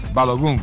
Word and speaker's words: Balogun: Leandro Boldaquian, Estados Unidos e Bélgica, Leandro, com Balogun, ou Balogun, como é que se pronuncia? – Balogun.